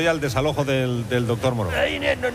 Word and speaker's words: Moreau: ¡Doctor Murro! ¡Doctor Murro, Carmen! – ¡Se pronuncia Moreau! ¡Doctor Moreau!